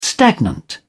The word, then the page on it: stagnant: British English